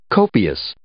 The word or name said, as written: copious